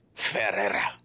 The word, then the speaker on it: Ferreira: Let's see, written by Ray Fox, art by Juan Ferreira. I'm probably saying that wrong. Probably butcher. – Ferreira.